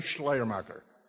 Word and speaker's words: Schleiermacher: Everybody say Schleiermacher. And you have to say it where you almost have to wear a mask because you're going to spit it out, good German pronunciation.